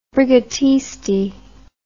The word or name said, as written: brigatisti